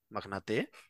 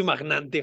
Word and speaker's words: magnate: ¿Magnate? No jodas. – Sí, claro, no se dice magnante, jamás. ¿Cuándo se escuchó la palabra magnante? Magnate.